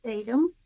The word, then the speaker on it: Statham: I don't know your histories quite as well, but Denise Statham, is that correct? Did I say that right, Denise? – Statham, yes.